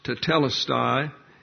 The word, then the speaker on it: tetelestai: It's actually pronounced tetelestai, and it is in the perfect passive indicative of the verb teleo.